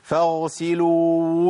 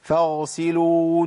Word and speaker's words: فاغسلوا: فاغسلوا وجوهكم. طب الخطأ فين يا شيخ؟ فاغسلوا وجوهكم، أنا ما طلعتش واو.